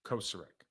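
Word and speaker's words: Kocurek: What I really like is that he's been coached by arguably now the best in the business, Chris Kurosek. Or I say his name wrong every time. Kosarek. It's Kosarek. He is, I was with him in Miami.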